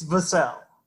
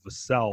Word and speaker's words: Vassell: Is it Vassell or Vassell for Devin? Because we want to get the right emphasis on the syllables here, correct? – It's Vassell. – Vassell. Okay, so we're emphasizing the second syllable, Vassell.